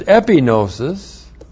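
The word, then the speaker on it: epignosis (ἐπίγνωσις): And then from there is gnosis, is having knowledge, and then there's epignosis, epignosis, E-P-I-G-N, long O-S-I-S, and that is completed knowledge, full knowledge.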